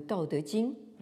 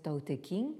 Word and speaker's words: Tao Te Ching: le Tao Te Ching, qu'on prononce quelquefois à la française Tao Te King, le livre canonique de la voix et de sa vertu ou de sa puissance.